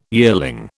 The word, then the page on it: yearling: British English